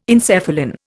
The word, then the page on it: encephalin: Indian